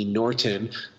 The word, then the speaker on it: Norten: Norton. My last name is spelled N-O-R-T-E-N. Every other Norton is pretty much N-O-R-T-O-N.